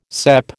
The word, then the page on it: cep: American English